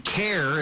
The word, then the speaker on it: CAIR: CARE is how people pronounce it, C-A-I-R.